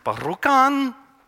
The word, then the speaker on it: Rjukan: It's pronounced, I think, I don't know, Norwegian, Rjukan, kind of like rock on if you want to look it up later.